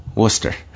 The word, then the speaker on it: Worcester: Worcester is the way you pronounce it? – Yes, Worcester. – Not Worcester. – Not Worcester. Worcester, it's Worcester.